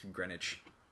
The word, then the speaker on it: Greenwich: Well, I mean, these are from the same culture that pronounces G R E E N W I C H is Greenwich.